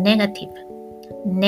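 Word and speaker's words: negative: ねえー、これも先ほどと同じように最後の VE の V のところね、下唇から浮かんでいただいたら OK です。ね、発音ネガティブ、ネガティブになります。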